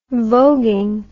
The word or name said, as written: vogueing